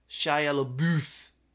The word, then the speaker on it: Shia LaBeouf: It's pronounced Shia LaBeouf.